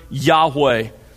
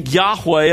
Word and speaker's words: Yahweh: It was probably pronounced something like I'm trying to do, Yahweh. I say probably because when the Hebrew was originally written, there were no vowel pointings.